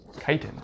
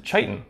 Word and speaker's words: chitin: Chitin. – And a lot of you might think that we would pronounce it chitin, but it's actually pronounced chitin with a K.